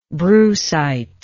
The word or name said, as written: brucite